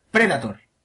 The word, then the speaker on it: Predator: Predator. Pre-da-tor, tilde en la E.